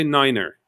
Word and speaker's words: niner: And the instructor will go, no, you have to say niner. You have to say niner because nine is no in German and it might be confusing. So we've agreed that the number nine is pronounced as niner.